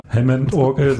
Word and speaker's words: Hammond: Du spielst ja nicht nur Harmon-Orgel. Habe ich das jetzt richtig ausgesprochen? – Gar nicht, das war alles falsch. – Aber du spielst ja nicht nur Hammond-Orgel, sondern auch Gitarre und so.